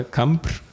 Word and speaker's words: Campr: I twoja firma, która nazywa się Campr, warto zwrócić bardzo uwagę na pisownię, tak, i wymowę, Campr,